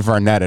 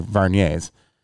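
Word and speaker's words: Vuarnet: So finally I'm like, hey, Rick, I'm like, are those, I pronounced it Varnet at first because that's how it's written, but I was corrected. They're Varniers.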